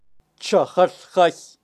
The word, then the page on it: chajal jay: Kaqchikel